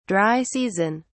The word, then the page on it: dry season: British English